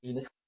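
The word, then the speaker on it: Aeneas: That's just pronounced like an E almost. Very little A, Enos.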